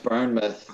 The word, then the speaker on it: Bournemouth: First of all, it's pronounced Bournemouth, yeah.